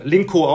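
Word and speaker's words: Lynk & Co: heißt ja bewusst so, ja, Lincoln, Linko, das Linko ausgesprochen, die Chinesen machen das ja echt geschickt, ja, mit Kopieren.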